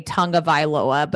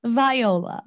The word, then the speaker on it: Tagovailoa: I always want to say Tagovailoa, but it's not. I throw an N in there. – Tagovailoa.